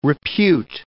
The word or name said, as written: repute